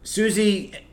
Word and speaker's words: Siouxsie: So Susie Sue, and it's spelled, by the way, S-I-O-U-X-S-I-E is the way she spelled her name.